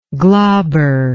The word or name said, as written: glaber